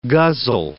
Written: guzzle

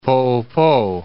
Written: Poopo, Lake

